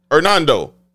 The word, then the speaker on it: Hernando: Because I'm pronouncing H. Hernando.